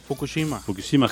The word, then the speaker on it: Fukushima: Fukushima, Japón.